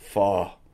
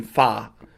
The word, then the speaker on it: pho: It's pronounced with an F-U-H. – F-U-H, so that's pho. – Pho. Not pho as in with an A sound, but pho. – Pho.